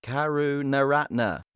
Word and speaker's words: Karunaratne: Karunaratne. – Oh, yes. He's perfect now. He's fine at Karunaratne.